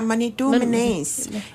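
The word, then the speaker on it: manidoominens: Yeah, in Ojibwe, it's pronounced Manidumanes? – Oh, yeah, Manidumanes.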